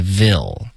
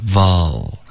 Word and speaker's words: devil: OK，Devil、 okay, 你发成 vil，你再看它字的拼写发音，这个不好，你最好是看那个音标，不是 vil，是 vol。